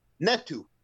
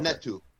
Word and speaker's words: Ntetu: And the third fight of the evening was David Lemieux versus, was it Francis Ntetu? – Ntetu. – Ntetu? – That's how you say it. Okay. Ntetu. – Good fight. – Think like an Egyptian prince. Ntetu.